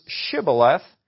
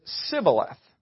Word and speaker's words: Shibboleth: They use a word that Gilead pronounces Shibboleth, and Ephraim pronounces it Sibboleth.